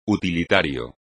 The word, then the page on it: utilitario: espagnol